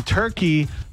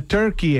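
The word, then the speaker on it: Türkiye: Apparently in 2022, the United Nations officially agreed to change the country's recognized English name from Turkey to Turkey, exactly how they pronounce it in their home country.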